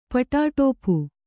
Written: ಪಟಾಟೋಪ

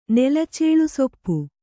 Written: ನೆಲಚೇಳುಸೊಪ್ಪು

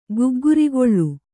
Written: ಗಗ್ಗರಿಗೋಲು

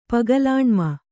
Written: ಪಗಲಾಣ್ಮ

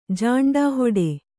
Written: ಜಾಂಡಾಹೊಡೆ

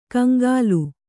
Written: ಕಂಗಾಲು

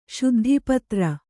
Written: ಶುದ್ಧಿಪತ್ರ

